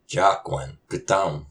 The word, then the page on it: Cáqan kutam?: Algonquian